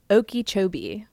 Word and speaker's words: Okeechobee: And this whole lobbying conversation takes us to Lake Okeechobee.